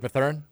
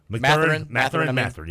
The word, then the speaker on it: Mathurin: Benedict Mathurin. – Mathurin, yeah.